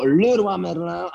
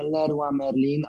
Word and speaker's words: Leroy Merlin: wymawiamy to Leroy Merlin, a nie Leroy Merlin, ani inaczej, ani i Antermarché, Antermarché, a nie a nie Intermarché.